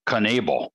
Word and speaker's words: Knebel: And I think I have the I always said Corey Knebel. And I'm looking him up on Baseball Reference and it looks like they pronounce him as Knebel.